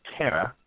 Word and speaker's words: Terra: That's T-E-R-R-A, Tara. Am I pronouncing that name, your first name correctly? – Yes, like Terracotta, Terra Firma.